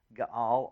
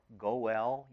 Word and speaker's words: Goel: Gaal or Goel, you might want to pronounce it that way, which can be translated as the kinsman redeemer.